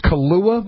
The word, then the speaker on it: Kilauea: I don't know if you pronounce this Kalua volcano. – Yep.